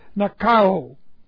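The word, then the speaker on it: Nikao (νικάω): N-I-K-A, long O, Nikao.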